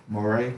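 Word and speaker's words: Morray: I wouldn't say I'm not necessarily checking for Cole on this one, but I was so pleased to see, how you say his name again? – Moray. – Mm-hmm. I be saying Murray on that. Moray.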